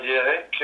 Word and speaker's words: Yerik: Eh bien, Yerik, il se prononce Yerik en français et en arménien, du coup, Yerik, ça veut dire 3, le chiffre 3.